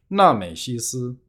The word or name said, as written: 纳美西丝